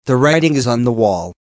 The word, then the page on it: the writing is on the wall: American English